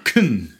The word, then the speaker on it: Kuhn: Han är Kuhn. – Vetenskapsteoretiken. – Och det är ganska många som säger Kuhn. – Jag vet inte riktigt hur man ska uttala det. K-U-H-N. – Kuhn. – K-U-H-N.